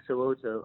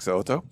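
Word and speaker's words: So'oto: And you got his name right, Vic Soto.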